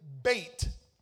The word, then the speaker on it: bet: Y'all know the B-E-T. But it's pronounced bait.